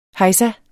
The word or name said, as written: hejsa